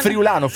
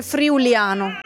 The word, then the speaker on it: friulano: Friulano. – No, l'errore di tutti è dire friuliano.